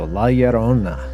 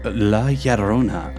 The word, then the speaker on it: La Llorona: We're going to do The Legend of La Llorona. Kind of a mouthful if you're not from the Spanish Southwest, but basically it's two L's, and two L's make a Y in Spanish, so La Llorona.